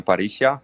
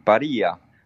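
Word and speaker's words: Parrilla: Są takie restauracje małe, które się nazywają Parisia. Pisze się to Parilla, czyli po hiszpańsku przeczytałoby się Paria, ale tam się czyta inaczej zupełnie.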